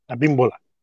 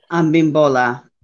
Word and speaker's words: Abimbola: And she has also received her hand of Orula from Baba Wande Ambola. – Abimbola. – Abimbola.